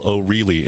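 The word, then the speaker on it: O'Rielly: Yeah, Commissioner Michael O'Reilly, and the I is before the E. Yeah, so it's pronounced like the owl.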